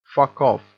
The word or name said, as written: fuck off